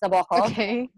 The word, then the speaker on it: Nabokov: By the way, it's pronounced Nabokov, but I'm very bad at that, so sorry if I say Nabokov.